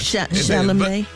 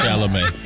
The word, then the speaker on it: Chalamet: And they said Timothée Chalamet and, honey, Kylie Jenner. – Chalamet. – Oh, that's how you say it. – Yeah, Chalamet.